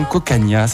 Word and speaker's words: Cocanha: Je vous redonne son nom, Cocania, ça s'écrit C-O-C-A-N-H-A.